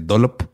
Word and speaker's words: Dollop: Se escribe Dollop con doble L, se dice Dollop.